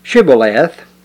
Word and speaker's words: Shibboleth: They pronounced SH like it was a plain S. And so when they would ask them to pronounce this word Shibboleth, they would pronounce it Sibboleth.